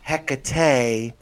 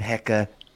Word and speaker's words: Hecate: But the other thing that makes it really interesting is that there are two pronunciations of the name. – Okay. – So there's Hecate and Hecate. Okay, and both are actually correct, but the mispronunciation, comes down to different syllables.